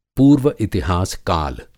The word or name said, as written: पूर्व-इतिहास काल